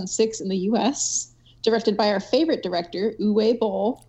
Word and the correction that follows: Uwe: Uva, Uva, come on. Uva, even, sorry, Uva, Uva. Even Michelle, even, even Michelle Rodriguez said it right in that video clip you sent us.